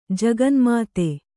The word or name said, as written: ಜಗನುಮಾತೆ